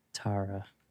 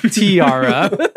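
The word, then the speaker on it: T-ara: Tara. – I will have to give it to you, even though the group is pronounced Tiara.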